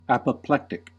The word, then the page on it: apoplectic: American English